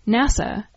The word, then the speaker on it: NASA: it's pronounced as a word, NASA, and not by spelling out the letters N-A-S-A.